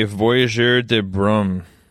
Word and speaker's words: Voyageur de Brume: I'm drinking a Voyageur de Brume. I don't know what that means. – Oh, you pronounce it correctly. – Thank you. That is the Brume's Journey. – Brume. Is it Brume? – B-R-U-M-E-S.